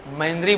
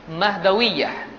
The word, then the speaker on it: مہدویہ: ایک فرقہ ہے مہدویہ, بہت سارے لوگ غلط اس کو مہندری بولتے ہیں, اب اسپیلنگ مسٹیک بہت سارے لوگوں سے ہوتی ہے. یہ فرقہ کون ہے؟ مہدویہ.